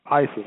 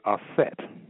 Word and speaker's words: Aset: It says here, ISIS, or you can say Aset. The correct name is Aset, or the correct pronunciation of Aset. The corrupt pronunciation is ISIS in the Greek term.